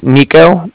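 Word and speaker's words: Niko: So my name's Nico. That's N-I-K-O. – Okay. – Calling with the Bank of Cardiff, and that's spelled C-A-R-D-I-F-F as in Frank Frank, so it's like car and then different.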